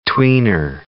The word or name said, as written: tweener